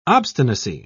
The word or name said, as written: obstinacy